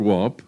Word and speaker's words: Weatherstonehope: Some time ago, when spending a delightful weekend at the ancestral castle of my dear friend, the Duke of Weatherstonehope, pronounced WAP, I came across an old black-letter manuscript.